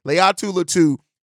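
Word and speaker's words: Laiatu Latu: Said it right that time, Leiatu Latu.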